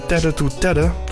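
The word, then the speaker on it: TETE2TETE: En ook op 19 september Plot Twist bij Tedder to Tedder. Zo schrijf je dat niet, maar zo spreek je dat misschien wel uit. Het is T-E-T-E en dan het cijfer 2 en dan T-E-T-E.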